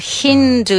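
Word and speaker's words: Hindu: Hindú. Y acentuamos la primera sílaba.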